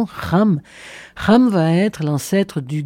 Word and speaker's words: Cham: Cham, en vérité, se prononce Ram et ça veut dire chaud, brûlant, bouillonnant.